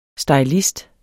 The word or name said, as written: stylist